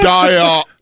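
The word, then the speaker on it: Dalla Riva: Peter Diarrhea. – Well, I'm assuming that two L's, and it looks like a Latino name. I'm saying two L's would be like a yuh sound.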